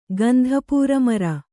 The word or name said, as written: ಗಂಧಪೂರಮರ